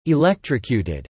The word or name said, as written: electrocuted